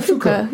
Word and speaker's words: Foucault: Well, am I saying it right then? – Yeah, Foucault. – Yeah. – Foucault. It's French.